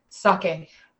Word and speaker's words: sake: We are trying, as I learned to say it correctly, sake.